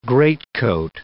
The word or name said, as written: greatcoat